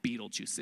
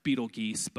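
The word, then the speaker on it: Betelgeuse: There's this planet called Beetlejuice. It's probably pronounced Beetlegeese, but I like Beetlejuice.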